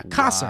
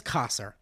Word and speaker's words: Kasser: Then the sir part like the word sir. Got it? No? Don't care? Perfect. So wait, let's try it now. Kasser. – Wow. – Yeah, Kasser. Wow. This man's name is Kasser. I was pronouncing it like the roll of bread the Jews love.